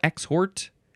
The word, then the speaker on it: exhort: Exhort or exhort. It's spelled exhort. The pronunciation says exhort. Whatever way you want to say it, I think is fine. E-X-H-O-R-T.